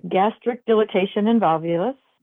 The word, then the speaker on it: gastric dilatation and volvulus: Gastric dilatation and volvulus.